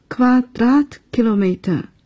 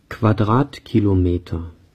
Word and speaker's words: Quadratkilometer: Berlin hat eine Fläche von 883 Quadratkilometern. – 句子里的 Fläche 是面积的意思。德语平方公里的读法是 Quadratkilometer，请跟着读一读。 – Quadratkilometer， Quadratkilometer。